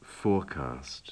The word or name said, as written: forecast